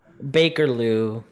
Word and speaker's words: Bakerloo: Beckerloo? – Bakerloo. – Nope. They're British. That's how they pronounce it.